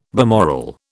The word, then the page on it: balmoral: British English